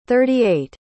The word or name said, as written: thirty-eight